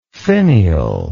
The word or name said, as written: finialed